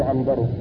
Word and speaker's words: عنبر: وفرق بين عبثر وعنبر إنما هو بالنقط، وأما الشكل فإنها متفقة، والفرق إنما هو بالنقط والاختلاف في الحروف بين الباء والتاء وبين النون والباء، الباء والتاء، الباء والتاء في الأول عبثر، والنون والباء في الثاني عنبر، فهذان